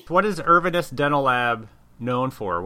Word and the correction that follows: Inverness: Inverness. Say that again. – What? Did I say it wrong? – Inverness.